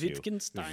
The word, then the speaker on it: Wittgenstein: Wittgenstein.